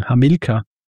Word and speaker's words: Hamilkar: Meistens bei diesem Konsens ankommen, dass bei Hamilkar wird es eben so basierend auf diesen lateinischen Regeln ausgesprochen. Hamilkar.